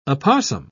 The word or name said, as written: opossum